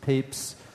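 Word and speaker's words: Papez: So another important figure in the history of research on emotion is this scientist named James Papes, it's pronounced Papes,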